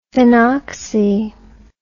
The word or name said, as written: phenoxy